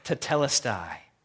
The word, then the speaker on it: tetelestai: It's pronounced tetelestai. If you want to write it down, you can kind of draw those Greek letters, or you can put T-E-T-E-L-E-S-T-A-I, tetelestai.